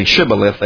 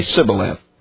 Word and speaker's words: shibboleth: There was something apparently about their speech ability that they could not say shibboleth. They would say sibboleth. Could not pronounce the H, apparently.